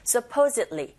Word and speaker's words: supposedly: 자, 여러분, supposedly로 발음이 들리지 않고 supposedly, 이 d 사운드가 z 할 때 그 받침으로 가는 느낌으로 연습하시면 돼요.